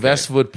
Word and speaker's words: Vestwood: Vestwood pronounced with, you know, it's like Westwood, but with V's.